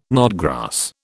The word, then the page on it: knotgrass: British English